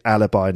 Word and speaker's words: alibi: And in English, yet, we pronounce it alibi, not alibi. I think in French it's alibi, but alibi in English.